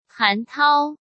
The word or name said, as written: Han Tao